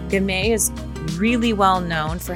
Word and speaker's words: Gamay: Gamay is how it's pronounced.